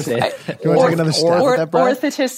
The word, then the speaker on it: orthotist: Orthotist. – So like orthotist. – Yeah. You put the emphasis is on the first syllable, like orthotist.